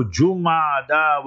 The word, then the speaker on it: Jumada: It is indeed only the grace and mercy of Almighty Allah Jalla Wala that today, the 5th of Jumad al-Ula, learn to pronounce the months correctly also. Jumada with the Dhamma Pesh on the Jeem.